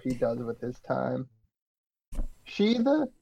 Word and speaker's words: Sidhe: Um so that that is Sid, uh S I D H E, right? Um the the like as in the Celtic fairies. – Um, yes, but it's, but it's pronounced, she.